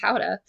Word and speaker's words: Gouda: Which, by the way, Gouda is actually pronounced howdah.